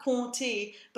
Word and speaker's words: compter: So this is the verb compter, but it's spelled with a p. – Yes. – But we ignore the p.